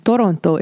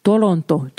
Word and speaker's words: Toronto: And Toronto is how you pronounce that. That's how my grandmother pronounced Toronto. Toronto, Toronto.